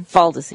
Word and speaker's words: Foldesi: Foldesi.